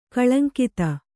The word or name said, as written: ಕಾಳಿಂಗದ